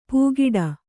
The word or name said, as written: ಪೂಗಿಡ